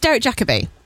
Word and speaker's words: Derek Jacobi: No, it's Derek Jacobi. That's how you say his name.